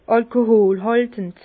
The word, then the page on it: alkoholhàltend: Bas-Rhin; Herrlisheim